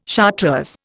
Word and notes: chartreuse: Indian

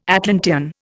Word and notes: Atlantean: Indian

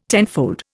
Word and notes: tenfold: Indian

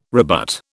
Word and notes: rebut: British English

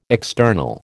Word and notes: external: American English

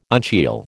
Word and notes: uncial: American English

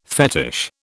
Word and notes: fetish: British English

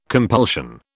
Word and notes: compulsion: British English

